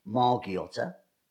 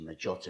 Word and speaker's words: Margiotta: They always called me because my surname is Margiotta, or actually Maggiotta in Italian, but everyone called me Maggie. Because they couldn't pronounce my surname.